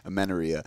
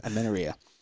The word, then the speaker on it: amenorrhea: Amenorrhea? Say it. – Amenorrhea. – Amenorrhea.